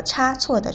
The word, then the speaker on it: 差错: 这个出差的“ 差” 和差错的“ 差” 是一个多音字，但是其实这个字啊，不仅有这两个读音，它还有“ 差” 和“ 呲” 的读音。